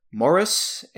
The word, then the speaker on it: Maurus: my next pick here, this is an artist, I believe her name is pronounced Elizabeth Morus, M-A-U-R-U-S, but her stage name is Lissie, L-I-S-S-I-E.